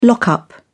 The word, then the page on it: lock up: British English